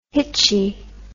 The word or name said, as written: hitchy